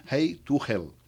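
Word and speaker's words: Highway to Hell: Yo sé que se escribe de esta forma, pero se pronuncia Hey to Hell.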